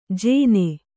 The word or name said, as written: ಜೇನೆ